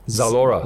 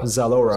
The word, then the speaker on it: Zalora: It's one of the largest e-commerce platform in Southeast Asia, and we know, uh, I don't know how to pronounce that, Z- Z- Zalora, right? – Zalora. Zalora. Zalora.